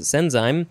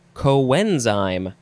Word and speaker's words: coenzyme: Next is, well, you know, based on the pronunciations of all these other ones, I would want to pronounce it senzyme, but it says it is pronounced coenzyme. So, yeah, the spelling is similar at the beginning, but it is the word co plus enzyme.